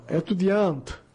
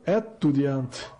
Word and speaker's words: étudiant: Como todas as palavras em francês são oxítonas, preste bem atenção, todas as palavras em francês são oxítonas, ou seja, só a sílaba final é tônica, o acento não marca a tonicidade de uma sílaba, como é o caso da palavra étudiant, que tem um acento, um acento agudo no E, né? Desse modo, ela é pronunciada da seguinte forma, étudiant, e não étudiant, né? Como leríamos em virtude do acento no E.